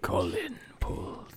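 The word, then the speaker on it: Kohlin Poold: Colin Pulled.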